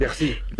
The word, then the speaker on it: Piercy: Piercy, muy rápido.